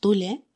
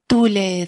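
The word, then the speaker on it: Thule: Bueno, nosotros en español pronunciamos Tule a través del latín, pero la palabra es griega y se escribía con Z, Omicron, ypsilon, Lambda y Eta, una Eta al final.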